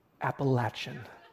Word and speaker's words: Appalachian: And yes, West Coasters, it is pronounced Appalachian.